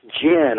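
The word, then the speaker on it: djinn: So it's jinn. The D is silent.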